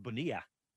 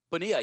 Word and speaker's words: Bonilla: So we had a blast in the shop today, but, you said Jake, I think if he's from San Antonio, it's probably pronounced Bonilla. – Bonilla, yeah. Jake Bonilla.